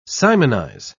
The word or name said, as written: simonize